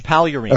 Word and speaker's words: Pagliarini: Well, you actually don't pronounce the G, but I'm still impressed. – Okay. – Paglarini.